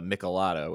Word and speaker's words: Michelada: And I was given a Michelada, which I'm sure you probably know what that is, but it's like a, how would you describe it?